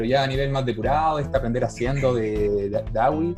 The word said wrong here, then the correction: Dewey: creo que era, eh, ¿cómo se pronuncia? – Dewey. Y, John Dewey.